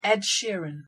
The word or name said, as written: Ed Sheeran